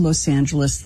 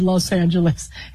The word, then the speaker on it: Los Angeles: How to pronounce Los Angeles. – You explained to me that you had come back to Los Angeles, that you had gone through... – You just said Los Angeles.